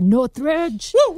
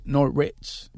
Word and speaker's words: Northridge: Mi hermanita pequeña tiene 28, 27 años de edad, que por cierto se va a graduar este domingo de Cal State Northridge. Cal State, ¿cómo se dice? – That's right. Cal State Northridge.